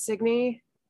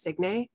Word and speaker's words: Signe: And I never knew how to pronounce Signy because it's Signe. So I always thought it was Signe. But Signy sounds cuter.